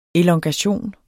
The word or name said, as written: elongation